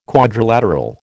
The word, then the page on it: quadrilateral: American English